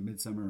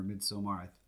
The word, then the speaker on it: Midsommar: And if you hear us pronouncing it Midsummer or Midsommar, the director actually said it could be either way, I think.